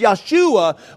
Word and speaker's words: Yahshua: This Yahshua, which is also the same way you pronounce the name Jesus in Hebrew, he was the son of Yosadak.